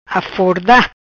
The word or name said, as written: Aforda